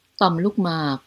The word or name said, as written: prostate